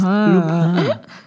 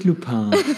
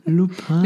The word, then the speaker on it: Lupin: Es heißt Lupin. Nicht Lupin. Es heißt Lupin.